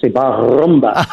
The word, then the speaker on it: Bar Rumba: I think you have to roll your R's when you say that. Well, yes, Bar Rumba.